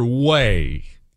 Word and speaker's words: Hue: Okay, this took place near Wei. Wei. You've probably, it's spelled H-U-E.